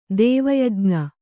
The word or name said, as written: ದೇವಯಜ್ಞ